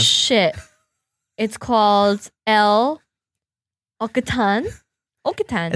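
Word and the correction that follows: L'Occitane: It's pronounced L'Ocitan, and it's from Paris.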